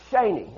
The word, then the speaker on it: sheni: And if you're taking notes now, it's this Hebrew word, and it's sheni, and that's how it's said. It's spelled S-H-E-N-I-Y, but it's pronounced sheni.